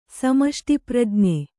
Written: ಸಮಷ್ಟಿಪ್ರಜ್ಞೆ

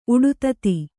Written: ಉಡುತತಿ

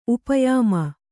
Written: ಉಪಯಾಮ

